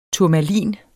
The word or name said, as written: turmalin